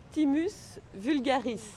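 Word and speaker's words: Thymus vulgaris: Timo vulgare. – Alors, on peut prononcer le S, donc c'est Timus vulgaris.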